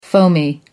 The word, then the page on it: foamy: American English